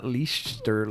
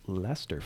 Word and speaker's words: Leicester: By the way, it's pronounced Leicester, not Leicester or Leicester. – Leicester. – It's Leicester, folks.